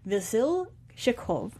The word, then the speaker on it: Vasilchikov: One is that she has a lover, Alexander Vasilchikov, and right. I believe I pronounced that correctly. – Right.